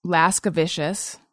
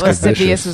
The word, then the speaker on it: lascivious: Laskavicious. That's when I was real young. I thought Lascivious was Laskavicious.